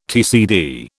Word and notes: TCD: British English

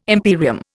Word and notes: imperium: Indian

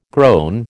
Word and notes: groan: American English

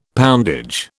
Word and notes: poundage: British English

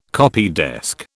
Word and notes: copydesk: British English

